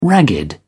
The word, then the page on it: ragged: British English